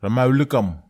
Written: ramawlekam

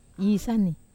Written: isani

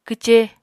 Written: kwce

